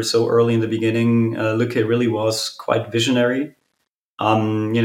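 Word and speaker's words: Lykke: How is it pronounced? Lücke? – It's called Lücke, yeah.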